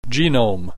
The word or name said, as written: genome